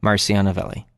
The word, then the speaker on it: Marcio Novelli: You can follow me on Twitter, Instagram, Facebook, Patreon, and Spotify, which are all my name, Marcio Novelli. M-A-R-C-I-O-N-O-V-E-L-L-I.